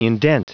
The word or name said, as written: indent